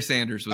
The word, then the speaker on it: Sanders: His first name is Barry and his last name rhymes with Danders. Barry Sanders.